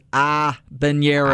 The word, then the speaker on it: habanero: It does start with an H. However, the Spanish pronounce it habanero.